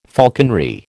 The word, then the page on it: falconry: American English